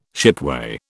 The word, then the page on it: shipway: British English